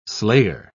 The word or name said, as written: slayer